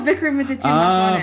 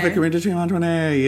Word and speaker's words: Vikramaditya Motwane: No, Vikram Aditya Mantwane. Oh, Vikram Aditya Mantwane.